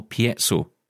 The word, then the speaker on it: piezo: So you can choose between Orbit's signage quality and affordable True Braille, as they call it, or traditional piezo cells. I think I'm saying that right. – Piezo.